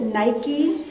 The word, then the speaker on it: nike: That word victory, Nike, we pronounce it Nike, swoosh.